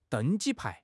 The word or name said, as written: deng1 ji1 pai2